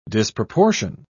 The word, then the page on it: disproportion: British English